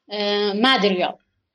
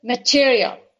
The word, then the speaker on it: material: He reached to a word, and that word was material, and he pronounced it with wrong stress, you know. He said material, and the teacher corrected him and said material.